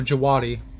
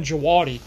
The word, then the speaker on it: Djawadi: Oh, Jawadi. – Okay. – Yeah, these are... – The D is silent. – It's kind of like Django. – Ah, yes. Yeah. Ramin Djawadi. – Djawadi.